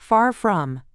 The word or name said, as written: far from